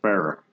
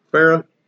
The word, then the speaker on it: Farrah: He says it's pronounced Farrah. – Farrah, like Fawcett.